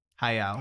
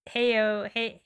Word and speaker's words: Hayao: Hayao.